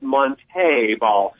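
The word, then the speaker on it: Montee: Did see, had a hint of diva when he said it's Monte Ball. Yeah, to emphasize the accent on the second E.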